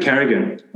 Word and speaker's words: Carrigan: But Cardigan, when he comes back. – I knew you were going to say that. – Well, what's Cardigan? – Patrick Carrigan. – Patrick Carrigan.